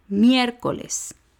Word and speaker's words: miércoles: Miércoles. Son tres sílabas, miércoles. La última sílaba es les, la penúltima es co y la antepenúltima es mier.